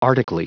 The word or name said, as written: arctically